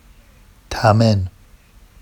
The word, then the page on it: tamen: Vafsi